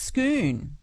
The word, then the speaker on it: Scone: Scone. – It is. It is like a schooner.